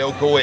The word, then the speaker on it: Ocoee: The Ocoee River. O-C-O-E. Just like they pronounce it here in Florida.